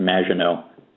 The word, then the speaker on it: Maginot: And that word is pronounced Maginot.